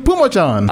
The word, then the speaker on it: Pumachon: Tyson Pumachon? That's it. – Pumachon? – Yes. – Why did you have like six? – It's Pumachon. – I was adding syllables.